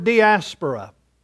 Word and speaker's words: diaspora: It is the Greek word diaspora. And if you're taking notes, let me just spell it for you. It's D-I-A-S-P-O-R-A. D-I-A-S-P-O-R-A.